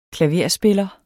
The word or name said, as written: klaverspiller